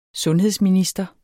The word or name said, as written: sundhedsminister